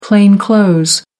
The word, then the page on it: plain clothes: American English